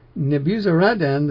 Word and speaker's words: Nebuzaradan: And some of the lowly ones of the land, Nebuzaradan, excuse me, I have not been pronouncing that correctly all my life. Nebuzaradan, the chief of the bodyguard, let remain as vine dressers and as compulsory laborers.